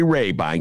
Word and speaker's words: Rabine: That's how you pronounce the name, people. Gary Rabine.